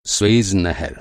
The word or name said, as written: नहर